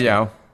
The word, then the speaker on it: Hayao: How do you say his first Hayao.